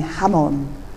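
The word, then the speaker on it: jamón: She practiced on her tongue the Spanish pronunciation with a soft H instead of a Z. Javier, not Xavier. Like Jamon becoming Hamon.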